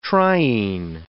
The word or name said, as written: triene